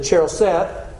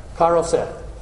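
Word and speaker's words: charoset: and you're going to dip it in the cheroseth, what's the other way we pronounce that? It's what? – Karoseth.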